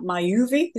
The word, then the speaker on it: Myoovi: And Coni works with brands including Holland & Barrett, Parla, I don't know how to say that, Myuvi, is that how you say it? – Yeah, that's right.